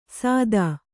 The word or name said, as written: ಸಾದಾ